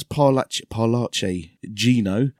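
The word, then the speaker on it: Parlaci Gino: So yeah, that's Paolucci Gino. – I'm glad you got the pronunciation.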